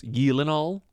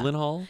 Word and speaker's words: Gyllenhaal: I think the real pronunciation is Gyllenhaal. – Yeah. – Gyllenhaal. – Because Hall is H-A-A-A-H-A-A-L, right?